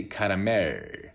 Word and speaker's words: caramel: Yeah, I think what comes to attention is the L sound at the end, right? It's easier to say caramel with a rir again.